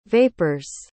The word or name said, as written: vapors